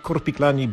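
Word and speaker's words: Korpiklaani: Czyta się tak jak napisano, czyli Korpiklani.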